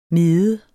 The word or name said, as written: medet